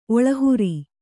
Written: ಒಳಹುರಿ

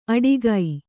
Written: ಅಡಿಗೈ

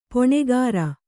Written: ಪೊಣೆಗಾರ

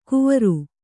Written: ಕುವರು

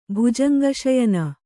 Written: ಭುಜಂಗಶಯನ

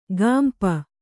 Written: ಗಾಂಪ

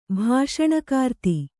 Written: ಭಾಷಣಕಾರ್ತಿ

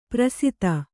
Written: ಪ್ರಸಿತ

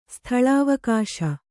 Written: ಸ್ಥಳಾವಕಾಶ

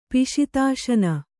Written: ಪಿಶಿತಾಶನ

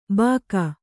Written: ಭೋಕು

